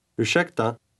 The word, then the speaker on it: ursäkta: Ursäkta. – Note that the S in word 9 is pronounced sh because it follows an R.